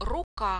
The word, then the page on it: ruka: Russian